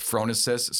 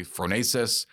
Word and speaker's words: phronesis: The Greek word in Aristotle is pronounced differently by different people. I would go with phronesis. Some people say phronesis, but it's so it's alternately translated as practical wisdom or prudence.